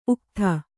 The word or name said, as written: ಉಕ್ಥ